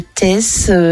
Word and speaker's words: Theys: C'est une maman de Tess. C'est un village, ça s'écrit T-H-E-Y-S, qui a appelé les pompiers pour un accouchement en urgence.